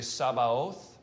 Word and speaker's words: Sabaoth: Yahweh Tsebaoth, which we usually say Sabaoth, right? S-A-B-A-O-T-H.